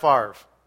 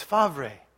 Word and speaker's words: Favre: If I say Brett Favre, it drives her nuts, because she says, "That is not how you pronounce his name. It's Favre." And I said, "No, it's Favre." She says, "No, it's Favre, just nobody knows it."